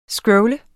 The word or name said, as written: scrolle